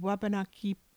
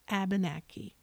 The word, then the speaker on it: Wabanaki: So the word Wabanaki describes the people of the Northeast. Waban is white, Aki is land, people from the white land or the dawn land where the sun first rises. And the English pronounce that word as Abenaki.